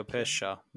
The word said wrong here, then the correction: Pecchia: isn't it? – Fabio Pecchia.